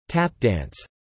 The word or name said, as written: Tap dance